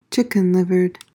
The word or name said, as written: chicken-livered